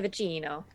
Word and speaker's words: Vecchino: Yes. Italian. Mia Vecchino.